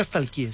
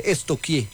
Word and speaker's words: estoquié: Porque no se dice nunca stalkeé, se dice estoquié.